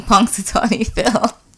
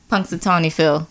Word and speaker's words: Punxsutawney Phil: Ooh, Punxsutawney Phil. – Wait, say that again. What's his name? – Punxsutawney Phil. – Wow, say it one more time. – I can't. – Exactly. – Punxsutawney Phil.